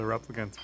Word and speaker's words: replicants: There you go, the replicants, for sure.